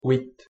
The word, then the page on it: huit: French